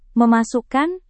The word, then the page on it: memasukkan: Indonesian